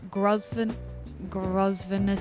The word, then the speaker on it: Grosvenor: And then in the city of Sydney, affecting the Western Distributor just past Grosvenor Street, silent S, thank you Verge.